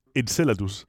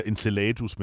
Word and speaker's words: Enceladus: Jeg er ikke sikker på, det sådan, man udtaler Der er nogen, der siger enceladus, men det lyder bare så fordansket, at jeg siger enceladus.